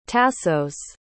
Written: tassos

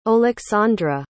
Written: Oleksandra

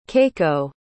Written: keiko

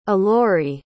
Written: ilori

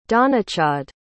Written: donnachadh

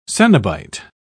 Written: cenobite

